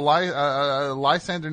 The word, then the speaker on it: Lysander: S-A-N-D-R. – E-R. – E-R. L-Y-S-A-N-D-R-E-R. – No. L-Y-S-A-N-D-E-R.